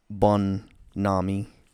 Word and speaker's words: bonhomie: And it's bonhomie. – Bonhomie. – Which is French, actually. But sounds like baloney. – But it's spelled bonhomie, B-O-N-H-O-M-I-E.